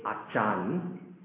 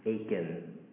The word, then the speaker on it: Achan: By the way, all CHs in the Old Testament are K. Right? So you don't say Nebuchadnezzar. You don't say Achan, but you say Achan. Okay? So that's, you know, one piece of nugget for you. Okay? CHs, with the exception of cherubim, is always pronounced K. Alright? With the K sound.